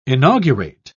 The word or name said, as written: inaugurate